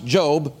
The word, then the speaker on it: Job: It's pronounced Job.